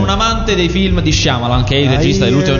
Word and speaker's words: Shyamalan: Si dice Shyamalan. – Ma non c'è una I. – Ho capito, ma si pronuncia così. Come io. – Si pronuncia. – Comunque si dice Shyamalan, scommettiamo.